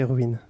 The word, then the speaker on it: Ruins: Yeah, we don't know how to euh, pronounce uh, runes. On ne sait pas comment prononcer ruine. – Ruine, ruine. I think here it's a uh, ruin, ruine minuscule.